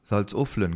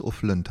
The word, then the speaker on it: Salzuflen: Salzuflen gibt es auch. Das ist, alle sprechen das in Salzuflen aus, aber es ist Ufflen tatsächlich.